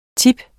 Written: tips

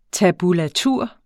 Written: tab

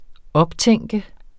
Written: optænke

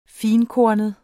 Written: finkornet

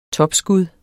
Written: topskud

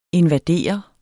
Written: invadere

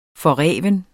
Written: forreven

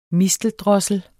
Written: misteldrossel

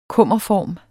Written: kummerform

